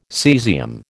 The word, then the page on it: caesium: American English